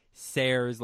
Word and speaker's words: Sayers: Sayers. – Dorothy Sayers, like stairs without the T, which I still have trouble saying. I can say it. I've discovered I can say Sayers, sort of, if I'm not saying it in a sentence.